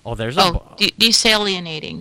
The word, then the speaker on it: desalinating: The sunflower solar harvester, developed by the Swiss company Airlight Energy, can do all that in the process, producing heat, desalinizing water. – Desalinating. – Desalinating. Whatever. – Desalinating. – Desalinating. Didn't I say desalinating? – I don't know.